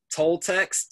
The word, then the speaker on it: Toltecs: How do you say it again? Toltecs. T T as in Thomas. O L T as in Thomas. E C S.